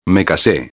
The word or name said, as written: me case